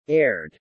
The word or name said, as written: aired